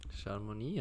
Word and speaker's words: Chamonix: in Chamonix. Chamonix, ja.